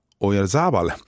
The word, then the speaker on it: Oyarzabal: Och jag vet att uttalet har diskuterats i sportsammanhang. Jag väljer att säga Oyarzabal.